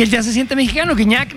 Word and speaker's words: Gignac: Así se pronuncia, ¿verdad? – Gignac. Que no Gignac.